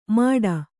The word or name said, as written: ಮಾಡ